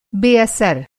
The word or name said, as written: बेअसर